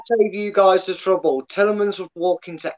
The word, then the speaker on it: Tillemans: Talisman? I never know how to pronounce it. – Tillemans. – That's the one, Tillemans.